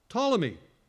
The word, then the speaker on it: Ptolemy: It's P-T-O-L-E-M-Y. Starts with a P, but it's pronounced with a T. Go figure. Ptolemy.